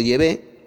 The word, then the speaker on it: Yahvé: El nombre falso Jehová lo he cambiado por Yahvé, ya que esta es la pronunciación correcta en hebreo arcaico del nombre de Dios.